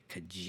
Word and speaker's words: Kijiji: Kijiji.